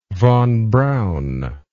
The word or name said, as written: von Braun